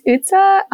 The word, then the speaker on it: Utsa: So my name is Utsa.